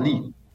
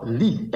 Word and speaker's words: Leith: Yes, Akua Lee. – Akua Lee. – Akua Lee, L-E-I-T-H.